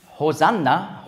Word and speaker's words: Hosanna: Also die Evangelien geben genau die Aussprache, die damals üblich war, wieder, mit Hosanna und nicht Hosi'anna.